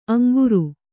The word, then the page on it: ಅಂಗರ: Kannada